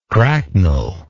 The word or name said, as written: cracknel